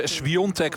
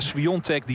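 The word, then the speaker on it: Świątek: Het is Swiatek, want zo spreek je dat op zijn Pools uit. Iga Swiatek, die 6-2 en 3-0 voorstaat.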